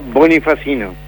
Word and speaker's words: Bonifacino: En realidad, Bonifacino. – Ah, Bonifacino, porque me está faltando una sílaba, hermano, acá. – Una N, se pierde bastante seguido.